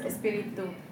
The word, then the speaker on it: espíritu: So the T's are soft T, like not t, but like your tongue's like that against the teeth instead of like that. – So like instead of being espíritu, now I can't say it wrong, sorry. But instead of like two, it's espíritu.